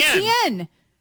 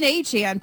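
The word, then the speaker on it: Achane: And so apparently, A-Chan, who was formerly, the artist formerly known as ETN, says that it's always actually been A-Chan, but we've always been pronouncing it right with wrong with ETN.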